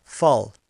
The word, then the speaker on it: phala: When that reaction does occur, then it's known as something called karma phal. K-A-R-M-A, karma, and phal, P-H-A-L-A. The last A is silent.